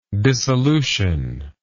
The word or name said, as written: dissolutive